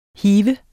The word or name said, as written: hive